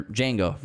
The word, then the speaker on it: Django: So he has both Django's together on the same shot, which is really cool the to D see. Is silent.